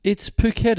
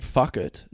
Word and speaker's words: Phuket: It's Phuket. It's not fuck it.